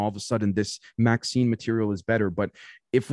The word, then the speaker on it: MXene: They said it's pronounced Maxine. – Or like Maxine. – Like the female name Maxine.